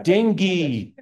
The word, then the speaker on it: dengue: Dengue. Yes. Oh, okay. Because you spell it funny. So I just wasn't sure.